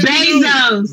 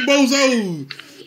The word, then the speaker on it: Bezos: If I'm saying his name wrong, Bezos. – The way people be saying it, they say Bozo. I mean, you know what? You're right. I'm looking at it because it's a B. It's a B-E. I'm saying it like a boat. B-O.